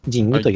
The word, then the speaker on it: 神器: えー、問いの 17 は、えっ、ー、と、仁義、えっ、と、神の器と書いて、仁義と読む、はい、あのタイトルになっております。